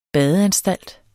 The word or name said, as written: badeanstalt